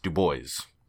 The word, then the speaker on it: Du Bois: but he had reasons for insisting that his name be pronounced Du Bois.